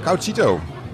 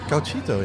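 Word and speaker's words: Gauchito: En eentje met, uh, heet, zeg ik het goed, Couchito. – Ja. Cauchito, ja, inderdaad.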